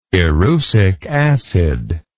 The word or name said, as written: erucic acid